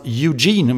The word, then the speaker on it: Eugene: I någonting som ska uttalas Eugene, om jag förstår saken rätt. Oregon.